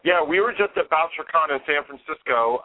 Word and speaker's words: Bouchercon: Is it called, pronounced BoucherCon? – Yeah, BoucherCon, yeah.